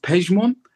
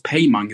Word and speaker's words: Pejmon: Also das Ding ist, mein richtiger Name im Persischen ist Pejmon. Aber das haben wir dann damals umgeändert, weil dann das konnte man halt in Deutschland nicht aussprechen. Man hat Pejmon gesagt, die haben das Payman gesagt.